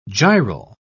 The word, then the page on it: gyral: British English